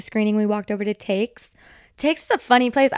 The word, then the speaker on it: Taix: And it's a French restaurant, but it's pronounced Takes. T-A-I-X. But you pronounce the X.